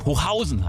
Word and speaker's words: Hochhausen: Im Gegensatz zum Gebäude wird aber die zweite Silbe betont. Also Hochhausen heißt es eigentlich.